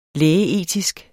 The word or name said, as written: lægeetisk